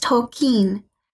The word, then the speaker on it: Tolkien: So before you start to lose me, can you address what the correct way to pronounce Tolkien is? Is it Tolkien or Tolkien? – Great question from our listeners. So I think this is probably because of my very bad American accent, but I've been pronouncing his name wrong. Oh no. – Oh boy. – The correct way is Tolkien.